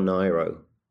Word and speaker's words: Nyro: Can I just check, is it pronounced Nyro or Nyro? – I believe it's Nyro, because her name was Nigro.